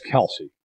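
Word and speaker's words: Kelce: It's Kelsey. It's Kelsey. Yeah. Just stop. It's as someone who has a last name that also ends in an E that gets butchered constantly, listen, it's Kelsey. Okay? He's gone by Kelsey his entire career.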